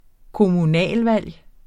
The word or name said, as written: kommunevalg